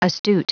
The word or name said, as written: astute